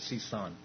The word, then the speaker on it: Sison: I actually went back to the original pronunciation of my last name, which is Sison.